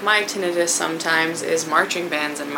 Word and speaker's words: tinnitus: But one of the most common symptoms to pay attention to is tinnitus yeah, it's pronounced tinnitus, not tinnitus. Common mistake.